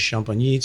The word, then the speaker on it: Champigny: And also, just people listening, you should try Samur, and then the little village of Champigny, spelled like Champ, Igny, and Samur is Saumur, just so you don't go in asking for s'mores at the wine store.